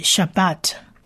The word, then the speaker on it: Shabbat: 这个字呢在发音上我们发特的音，所以安息日的讲法就是 Shabbat Shabbat。